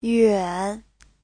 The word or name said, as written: yuan3